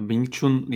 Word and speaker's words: Wing Chun: Wing Chun diye okunuyormuş. Teşekkür ederim beni bozmadan düzelttiğin için.